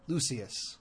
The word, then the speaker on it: Lucius: Because he agrees, Lucius, right? – Lucius. – There's an extra syllable in there. Lucius.